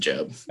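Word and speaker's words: Jobe: Did we decide it's Job or did we mispronounce it again? – Yeah, I believe it's Jackson Job.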